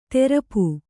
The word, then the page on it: ತೆರಪು: terapu